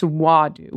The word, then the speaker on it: Wadu: It's Wadu with an ah, like saying ah when a doctor wants you to look at their throat.